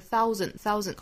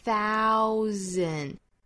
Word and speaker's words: thousand: 那我们再看 thousand 这个单词，首先 th 是舌头要伸出口腔外头哈，去摩擦上排的牙齿发 th, th, 那还要注意里面那个双元音是 ow 的发音，一定有很多同学会读成 thousand 口型不到位，应该是 thousand 一定是非常夸张的口型，英语里头这个 ow 这个发音在我们汉语发音里是找不到对应的，